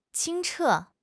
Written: 清澈

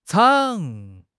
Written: zhang3

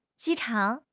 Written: ji1 chang3

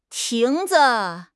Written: 亭子

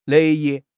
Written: 乐意

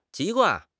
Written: 籍贯

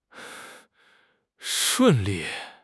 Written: shun4 li4

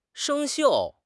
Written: 生锈

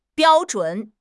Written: biao1 zhun3

